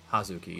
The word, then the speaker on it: Hazuki: She defended her IWGP Women's Championship against Azume and Hazuki, if I'm saying that correctly. – Hazuki? – Hazuki, yeah, that's how they say it.